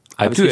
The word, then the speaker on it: Alpe d'Huez: So, Alpe d'Huez. Alpe d'Huez? – Alpe d'Huez. – Richtig gesagt? – Alpe d'Huez hast du richtig gesagt.